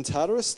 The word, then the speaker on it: Tartarus: In Tartarus, that's how you say it, yeah.